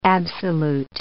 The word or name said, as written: absolute